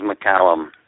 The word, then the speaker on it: McCallum: Just incidentally, for the record, the name is McCallum. – It's McCallum. – There are so many pronunciations that people come up with, but I just have to let you know that's the one I was born with.